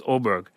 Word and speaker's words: Åberg: So Scheffler at 7, Murakawa at 6, Homer at 5, Åberg, it's capital A, but it's pronounced Åberg, DeChambeau 3, Åberg is 4, DeChambeau 3, and then Schauffele, Davis, Haggard are 2.